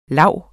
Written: lav

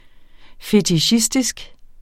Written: fetichistisk

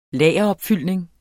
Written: lageropfyldning

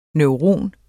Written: NEURON

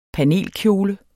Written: panelkjole

